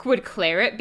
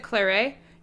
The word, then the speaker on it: claret: Would claret be okay, right with you? Anything, but don't you pronounce it claret? No. Claret. You pronounce the T, just as in fillet.